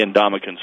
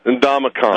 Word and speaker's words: Ndamukong: It's funny and it's not funny, because, you know, by now most people have kind of learned to say Ndamukong Suh.